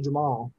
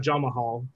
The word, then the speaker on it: Jamal: So let's start with the first fight of the night, which I found out it's actually Jamal, not Jamahal. The extra syllable is just silent, I guess.